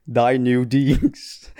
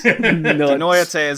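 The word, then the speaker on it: Die Neue These: You pronounced that wrong on purpose. – Of course I did. – I love it. Die New Deeds. – Die New Deeds. – Denoyate is is the ED specifically.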